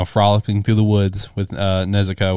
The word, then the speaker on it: Nezuko: I'm drawing a blank on her name, how to pronounce it. Nezuko? – Yes.